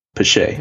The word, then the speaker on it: Pache: But it's not pronounced Pash. What is it? Is it Christian? What is it? – Pache. – Pache. Much better.